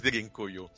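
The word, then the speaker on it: Derinkuyu: Una ciudad totalmente subterránea, se llama Dirincuyu, ¿vale? Lo estoy pronunciando a la española, pero es Dirincuyu.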